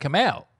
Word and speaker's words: Kumail: The show was called The Meltdown with Jonah and Kumail. – The Meltdown. Right. – Yeah. – That's Jonah Ray. – So before it was Jonah and Kumail, you had a show there, if I'm not mistaken.